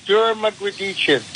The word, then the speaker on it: Dermgrditchian: And it's Mark, and excuse me if I butcher your last name, it's the longest author's name, Dermgrditchian? – Yeah, that's close, Dermgrditchian.